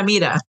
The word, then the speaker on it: Mira: If you speak Spanish, it's like Mira, yeah. Mira.